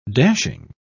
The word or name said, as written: dashing